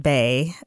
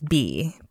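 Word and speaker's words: Bey: Steve, you actually, the pronunciation is not bay, it is bee.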